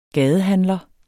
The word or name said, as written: gadehandler